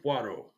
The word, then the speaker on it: Poirot: Hercule Poirot.